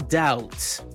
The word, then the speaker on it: doubt: The B in doubt.